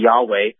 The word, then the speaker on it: Yahweh: There's no J sound in the Hebrew language. The most approximate pronunciation of God's name would be Yahweh.